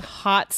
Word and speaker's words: haute: And, so, and the title says Hot Sauce Soiree. And hot is spelled H-A-U-T-E.